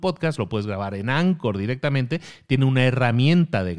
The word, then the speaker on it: Anchor: Se pronuncia Anchor, se escribe Anchor, es un ancla, digamos.